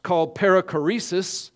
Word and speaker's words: perichoresis: and it's called perichoresis, that's pronounced correctly, and it means interweaving.